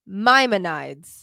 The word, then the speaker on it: Maimonides: It's I'm going to try. – Go for it. – Mayamonides. – What? Where is it? – Right here. – Maimonides.